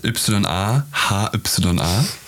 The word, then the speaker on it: Yahya: Buchstabiert Y-A, H-Y-A. Und Yvonne. – Das ist eigentlich, ursprünglich ist das ein arabischer Vorname. Mhm. Ja? Ein arabischer Vorname, der wird eigentlich Yahya ausgesprochen.